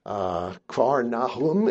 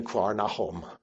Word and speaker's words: Kfar Nahum: And it says here, in leaving Nazareth, he, Jesus, came and dwelt in Capernaum, Kfar Nahum in Hebrew, Kfar meaning city or town of Nahum, as we would pronounce it in English. It's not the prophet Nahum, but the same name. So that's the meaning of the town. So sometimes I'll pronounce it in English, sometimes in Hebrew. But in Hebrew, it would have been Kfar Nahum or something very similar to that.